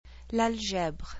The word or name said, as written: L'algèbre